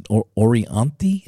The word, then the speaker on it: Orianthi: But it's the girl that played with Michael Jackson, Orianthi.